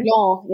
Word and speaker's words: Toulon: So it looks like your first port of call was Toulon. Am I pronouncing that right? – Toulon, yeah.